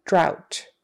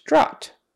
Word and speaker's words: drought: O-U-G-H spelling, O pronunciation. Then the last one is ow, ow. So bow and drought. Although sometimes that is pronounced drought. But anyway, it is an example of how you can pronounce O-U-G-H as ow, drought, meaning no water for a very long time.